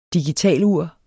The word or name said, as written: digitalur